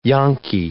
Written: yanqui

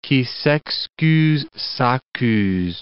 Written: qui s'excuse s'accuse

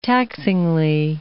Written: taxingly

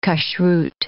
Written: kashruth

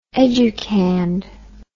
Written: educand

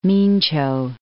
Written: Mincio

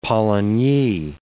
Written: polynyi

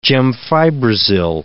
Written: gemfibrozil

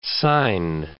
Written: sine

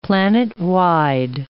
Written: planetwide

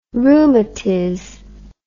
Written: rheumatiz

